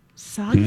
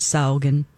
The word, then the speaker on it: Sogn: It's S-O-G-N. Soggy? – S-O-G. – Can somebody pronounce that for us? So, what is it? – S-O-G-N. – Sogn. – Sogn Valley Farm.